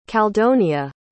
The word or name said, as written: caldonia